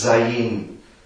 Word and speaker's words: Zayin: Weil das Zayin, das wird eigentlich, zwar wird es in der Übertragung immer meistens Zayin geschrieben, aber es wird nicht als Z ausgesprochen, sondern als sehr klingendes S, Zayin.